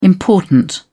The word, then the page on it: important: British English